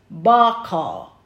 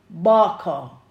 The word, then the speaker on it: bachar: So the Hebrew word for mighty is bakar, B-A-C-H-A-R, bakar. And it's pronounced B-A-W-K-H-A-R, bakar.